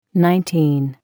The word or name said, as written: Nineteen